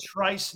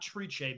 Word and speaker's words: Trice: So not Trice, not Triche, because the dam was a New York runner named Danza Triche, and they named it Tappet Trice. The letters are the same. The spelling is the same. Pronunciation is different.